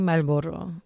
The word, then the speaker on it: Marlboro: In Spanish, we say Malboro. But I know that's not how you say it in English. It's probably like a, I think it's Scottish. Jay Marlboro. Jay Marlboro.